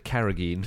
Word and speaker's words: carrageen: I said carrageen.